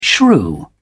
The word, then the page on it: shrew: British English